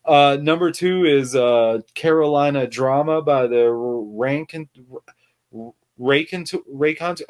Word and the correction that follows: Raconteurs: I don't know how to say the word. You know the band, right? Raycontours. No. – The Raconteurs? – Yeah, Raconteurs.